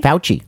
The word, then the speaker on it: Fauci: Fauci.